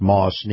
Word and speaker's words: Maus: And this portion of the program is brought to you by our very good friends at Moss Nissan. Moss is spelled M-A-U-S.